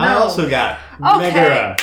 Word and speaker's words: Megara: I got Megara. That's how it's pronounced, right? Because mm-hmm. – I always want to say Megara when I see it written now. – Megara. – It throws me off.